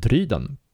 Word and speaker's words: Dryden: På svenska heter det helt enkelt dryden, som det stavas och som det låter. Och ingen jävla akut accent som det faktiskt är då, som är mitt efternamn, utan helt enkelt bara Dryden.